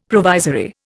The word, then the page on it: provisory: Indian